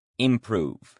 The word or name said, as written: improve